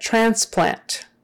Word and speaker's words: transplant: All right, transplant. Transplant, transplant. That's pretty straightforward, I think. Transplant. But anyway, the accent is on the first syllable.